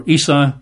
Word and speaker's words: Isa: They call him Isa. That's I-S-A, pronounced Isa.